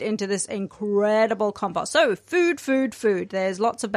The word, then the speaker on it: FUD: It is spelt F-U-D. And every year we have a conversation about is it pronounced FUD or is it pronounced food? I can tell you, I hopped onto their website. I had a look. It's pronounced food.